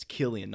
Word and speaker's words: Cillian: But I want to mention it's spelled with a C, but it is pronounced with a K. It's Cillian, not Cillian Murphy.